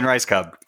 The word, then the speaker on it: Rajskub: It's spelled R-A-J-S-K-U-B. It's Rice Cup? – Cub. – All right. – Cub. With a B.